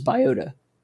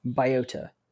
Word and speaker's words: Biota: and the next game on our list is Biota. – That's how you pronounce it. – Biota. Yeah. – Biota. – Or B-I-O-T-A.